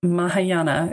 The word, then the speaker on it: Mahayana: So now I know that you, in your version of Buddhism, which is Mahayana, is that right? Did I say that right? – Yeah. Right.